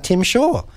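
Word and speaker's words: Tzim-Sha: The reason for that is that Tim Shaw is there, which is not how it's spelt, but that's the way everyone pronounces it.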